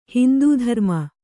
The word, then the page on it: ಹಿಂದೂಧರ್ಮ: Kannada; hindū dharma